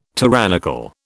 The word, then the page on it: tyrannical: British English